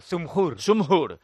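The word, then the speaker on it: Džumhur: Zumhur. – Ante el bosnio Damir Zumhur, pues 8:15, partido de noche de Alcaraz.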